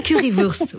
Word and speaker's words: Currywurst: Oui, la Currywurst. – C'est Currywurst, oui, c'est ça, c'est vrai, c'est Wurst. Oui, c'est, voilà.